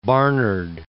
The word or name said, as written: Barnard